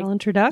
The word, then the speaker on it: Alan Tudyk: Alan Tudyk.